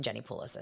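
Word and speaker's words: Jenni Pulos: Jeff Lewis and Jenny Poulos, I said that right.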